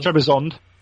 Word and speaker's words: Trebizond: It comes after the Varantian Heresy Hobby Camp, and that is Trebizoid. You can shoot me if I'm pronouncing this wrong, Chris. Please do. – It's Trebizoid. There's an N in there as well. – Trebizond.